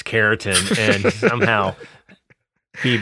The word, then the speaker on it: keratin: Did I say that right? Keratin. – Yeah, keratin.